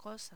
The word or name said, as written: cosa